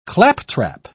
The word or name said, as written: claptrap